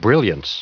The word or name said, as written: brilliance